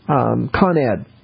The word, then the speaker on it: Con Ed: The utilities company, Con Ed. So it is. – Okay. – The emphasis on the con, as far as I'm concerned.